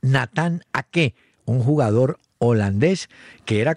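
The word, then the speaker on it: Aké: Y Nathan Ake, así dicen que se pronuncia, en una aplicación se llama Forbo.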